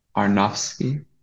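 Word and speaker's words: Aronofsky: How do you pronounce his last name? – Aronovsky. – Aronovsky. – Arnofsky. Arnofsky, yeah. Arnofsky. Okay, I haven't seen The Fountain.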